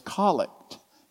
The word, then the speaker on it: collect: If you're new to the Anglican world, it looks like collect, but it's collect. It's pronounced collect.